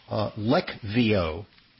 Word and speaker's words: Leqvio: Now, the medication that you're talking about, Leqvio, or it's tough to pronounce because it's got a Q in it. It's called Leqvio. It's a PCSK9 inhibitor, which means it's an ultra-powerful atom bomb for lowering your LDL cholesterol, way more powerful than statin drugs.